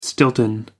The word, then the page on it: stilton: American English